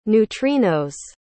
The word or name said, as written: neutrino's